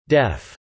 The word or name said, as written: deaf